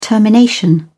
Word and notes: termination: British English

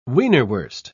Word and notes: wienerwurst: British English

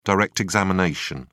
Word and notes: direct examination: British English

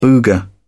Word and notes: booger: British English

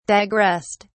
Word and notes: digressed: British English

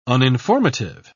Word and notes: uninformative: British English

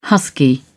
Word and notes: husky: British English

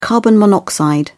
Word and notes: carbon monoxide: British English

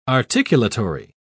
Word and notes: articulatory: British English

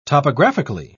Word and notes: topographically: British English

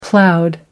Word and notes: ploughed: American English

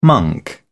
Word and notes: monk: British English

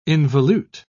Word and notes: involute: British English

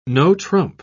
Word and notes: no-trump: British English

